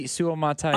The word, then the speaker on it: Suamataia: Kingsley Suamatia, I just butchered his name again. I'll get it down one of these days. Suamatia? I think that's it's right. I mean, yeah. Suamatia. Matatia. Matatia. – Kingsley Suamataia.